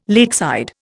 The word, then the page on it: lakeside: Indian